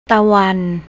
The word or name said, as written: tawan